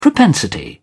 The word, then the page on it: propensity: British English